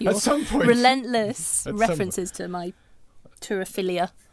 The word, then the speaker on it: turophilia: Your waterphilia? – Turophilia. – Is that what it's called? – Yeah. – I did not say that. Well, how do you spell that? – I think that's how you pronounce it. Turophilia. Yeah, T-U-R-O and then philia.